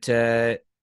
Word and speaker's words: Saoirse: And I can never pronounce her name, but Saoirse Ronan.